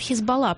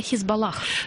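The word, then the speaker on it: Hezbollah: И Хизбаллу, кстати, по-разному называют, хотя на манер израильский, на манер английского языка говорят «Хизбалла», потому что «H» последний не читается, но при этом они называют себя «Хизбаллах».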